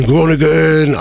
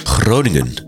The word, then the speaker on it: Groningen: This was recorded at one of our Vision Nights at Simplon in the hometown of Vision, Groningen. I can never pronounce it right. Can you pronounce your hometown correctly? – Groningen. – Groningen, there we go.